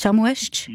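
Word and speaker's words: Chamues-ch: Herr Meier, habe ich es jetzt richtig gesagt, Chamouest? – Ja, richtig.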